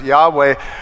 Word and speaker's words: Yahweh: so that when they came to the Yod He Vav He, which sometimes we pronounce Yahweh, they would instead say Adonai.